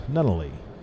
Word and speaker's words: Nunnaley: And I have with us this evening Robert Nunnaley from Pines Funeral Homes.